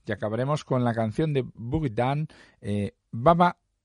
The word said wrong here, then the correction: Bada Boom: perdón, lo he dicho mal, ¿eh? Bada Boom es como se llama esta canción.